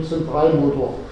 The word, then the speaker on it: Zentralmotor: Wichtig ist, man spricht von Zentralmotor. Die Betonung liegt auf Zentral.